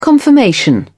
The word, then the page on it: confirmation: British English